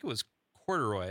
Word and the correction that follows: Couderay: Did you say Corduroy? – Am I saying that wrong? It's spelled phonetically. It looks like Corduroy. Corduroy? – Ends with an A. – Oh, no. Did I get Couderay. – Couderay. Oh, is that how you say it?